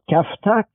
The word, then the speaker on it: kafta: It's called kafta, K-A-F-T-A. The closest I can say it like meatloaf, but with variety of different toppings. – Mm. Mm. Have you had kefta before? – I have not.